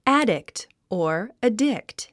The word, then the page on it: addict: ǽdikt; アディクト